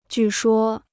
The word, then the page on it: 据说: jù shuō